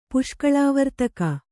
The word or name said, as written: ಪುಷ್ಕಳಾವರ್ತಕ